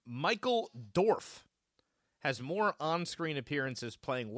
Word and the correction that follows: Dorn: Michael Dorn.